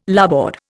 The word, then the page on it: larboard: Indian